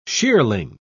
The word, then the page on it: shearling: British English